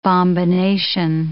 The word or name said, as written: bombination